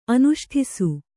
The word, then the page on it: ಅನುಷ್ಠಿಸು: Kannada